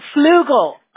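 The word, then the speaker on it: Fluedel: How do you pronounce that? Do you mind if I ask? – It's awesome. – Flugel. – Flugel. Oh, okay. So the C is almost like a G, right? – Yeah.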